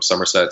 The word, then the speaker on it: Somerset: Somerset spelled like the English county, not like two names or a year or anything like that.